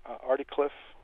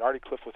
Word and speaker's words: Articlave: Well, it was a little lane outside of Articliffe in Northern Ireland. Articliffe, if you look in the map, you'll see it spelled Articlave, but it's pronounced Articliffe.